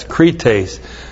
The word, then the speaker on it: krites: K-R-I-T, long E-S, krites.